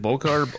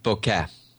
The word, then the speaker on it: bokeh: Is it bokeh or bokeh? What is it? – I think both pronunciations are acceptable. However, if we're basing it on the traditional Japanese, it would be more of bokeh.